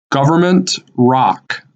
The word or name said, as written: Government Rock